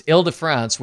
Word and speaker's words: Île-de-France: It's Île-de-France, which was a luxury liner in the 50s, but that's a whole other story.